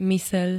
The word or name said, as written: myseľ